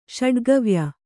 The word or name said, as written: ಷಡುಗವ್ಯ